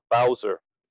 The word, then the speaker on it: Bowser: So the mayor of Washington City, Mayor Muriel Bowser, I think I'm saying that correctly, B-O-W-S-E-R, Bowser. – Bowser, yeah. – Yep. – Like the bad guy from Mario.